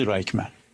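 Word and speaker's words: رایخمن (רייכמן): یه دانشگاه برگزارش میکنه، یه دانشگاه خصوصی، دانشگاه رایخمن یا به تلفظ رایخمن، یه دانشگاه خصوصی در اسرائیل.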